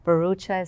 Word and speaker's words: Bharucha: Bharucha is spelled B-H-A-R-U-C-H-A. The H is silent.